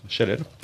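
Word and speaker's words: Chaleil: C'est comme ça qu'on prononce Chalel.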